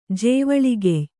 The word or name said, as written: ಜೇವಳಿಗೆಯ್